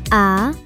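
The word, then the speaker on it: amie: Und Freundin heißt ami. Das spricht man genauso. Man schreibt es nur etwas anders.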